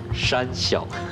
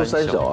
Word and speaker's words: 山小: 这个题目要非常小心的念山小。 – 山。 – 我我我说三小啊。